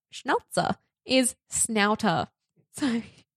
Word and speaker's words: Schnauzer: I think it's meant to be pronounced Schnauzer. – Schnauzer. – But I will say Schnauzer, not to embarrass myself. It sounds like a kind of like fancy soda water. – Yes. It kind of sounds like seltzer.